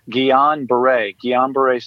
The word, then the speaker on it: Guillain-Barré: It's called Guillain-Barré syndrome, GBS.